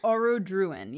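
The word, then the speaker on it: Orodruin: Is it Aurodruin? – Aurodruin, yes.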